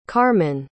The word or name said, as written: carmin